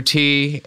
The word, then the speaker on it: Ti: I'm Andrew T, and last name is spelled T-I on...